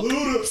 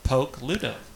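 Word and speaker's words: Pokluda: Poke, like I'm going to poke you. – Okay. – And Luda, like ludicrous. – Stop. – Poke. – Luda.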